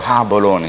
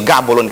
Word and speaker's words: Gaborone: Elimu limbia balozi hiyo inatamkwa Gaborone hiyo. Sio Gaborone kama watu wengi wanavyosema.